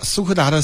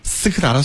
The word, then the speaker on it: 斯柯达: 这个戚坤说了，苏柯达的四派怎么样呢？斯柯达的速派怎么样呢？我怎么念瓢嘴了？ – 苏柯达的四派怎么样呢？ – 斯柯达速派。 – 其实这车是一个特别好的车。就是刚才我还跟张琪聊呢，因为我开斯柯达的，